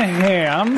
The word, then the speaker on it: Hamm: And then it says here, John Hamm. – Hamm, it's pronounced Ham.